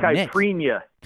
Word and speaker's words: caipirinha: It's a caipirinha.